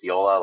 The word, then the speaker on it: JOOLA: Uh, Yola is J O O L A. It looks like Jula, but yeah, it's pronounced Yola, like YOLO.